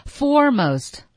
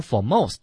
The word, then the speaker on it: foremost: 对，好、嗯、来看下一个词 foremost。 – 首先呢，这个好多同学会把它读成 foremost 啊，它的重音一定在前，因为它通常是个形容词，所以重音在前。